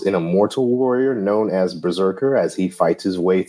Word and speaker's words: BRZRKR: As you heard in the singing intro, today is 8 Berserkers Berserking, which means we're unwrapping Berserker number 1 from Boom Studios in 2021. Now for a brief history of the series and the creators. Berserker, which is spelled B-R-Z-R-K-R,